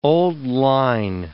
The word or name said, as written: old-line